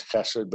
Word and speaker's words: chesed: In English, you would spell H-E-S-E-D. Sometimes it's spelled C-H-E-S-E-D, but it's guttural, chesed.